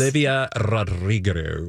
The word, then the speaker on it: Olivia Rodrigo: Olivia Rodrigo.